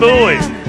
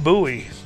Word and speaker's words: Bowie: Well, at least get his name right. It's Bowie, not Bowie.